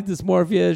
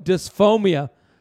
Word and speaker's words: dysmorphia: He even at one point told me about his body dysmorphia issues, but he kept pronouncing it dysph- dysphomia.